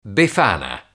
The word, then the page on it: befana: Italian